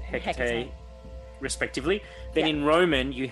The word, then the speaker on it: Hecate: So triple goddess in Greek system was Artemis, Selene, and Hecate. – Yeah. – Is that how you say it? Hecate? – Hecate.